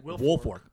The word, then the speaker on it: Wilfork: Wilfork.